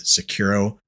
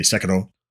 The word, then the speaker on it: Sekiro: Sekiro unbelievably run smooth on PC. I should say Sekiro, if I'm going to say it right, but unbelievably run smooth on PC.